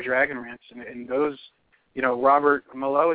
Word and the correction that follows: Mailloux: is that how you pronounce his last name? – Uh, Mailloux.